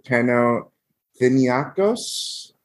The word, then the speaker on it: Panathinaikos: he is signed through the summer of 2022 with Panathinaikos. That's how you pronounce it.